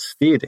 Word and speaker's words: Stead: Yeah, well, even the fact that you're pronouncing it Steed says that, like, it's Steed as in steady. Like, that's how you can remember it.